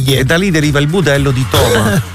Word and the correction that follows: Tomà: Di Tomà. – Ah, Tomà, l'accento. – L'accento è importante.